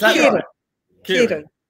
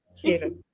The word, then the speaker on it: Kieran: It's Kieran. Kieran. Like an E sound.